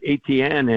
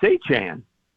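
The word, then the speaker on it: Etienne: And in his first press conference, you know, he says it's not ATN anymore. And I live not far away from a family of ATNs. They spell it the exact same way, said it that way. And he said, it's Achan.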